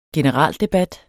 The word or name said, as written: generaldebat